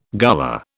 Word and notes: Gullah: British English

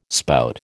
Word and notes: spout: American English

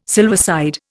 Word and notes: silverside: Indian

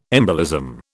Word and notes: embolism: British English

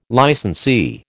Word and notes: licensee: American English

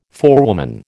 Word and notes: forewoman: American English

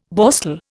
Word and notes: Borstal: Indian

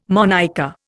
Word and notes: monicker: Indian

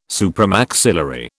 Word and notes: supramaxillary: British English

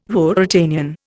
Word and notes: Ruritanian: Indian

